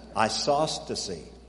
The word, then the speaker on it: isostasy: This verse is talking about the science of isotasy. Now, I didn't say that right. Isosceles triangle. Here, let me spell it for you. I-S-O-S. Isostasy. Isostasy. There it is. That's the word. This is the science of isostasy. I-S-O-S-T-A-S-Y.